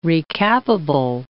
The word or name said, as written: recappable